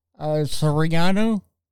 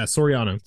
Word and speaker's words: Soriano: Yeah, but we interviewed Mr. Elias Soriano. Did I say it right? – Yeah, Soriano.